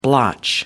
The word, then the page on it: blotch: American English